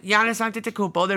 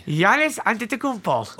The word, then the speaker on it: Giannis Antetokounmpo: They've got Giannis Antetokounmpo. They're building around him. – I like how you say that name. – Yeah, because that's actually how it's pronounced.